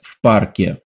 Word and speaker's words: в парке: Я сейчас в парке. – Я сейчас в парке. – We add that «ф» sound before the word «парк» and add the «е» sound at the end. «В парке».